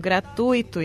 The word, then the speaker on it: gratuito: que pronunciei certo, gratuito e não gratuito, uma mania aí que muita gente tem, né, normal, com sílaba tônica no I.